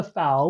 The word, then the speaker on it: FAO: It tells us that in 1945, the work of the FAO, the FAO, rhymes with cow. its motto is Fiat Panis, let there be bread. The work of the FAO was considered absolutely urgent,